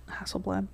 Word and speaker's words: Hasselblad: Hasselblad.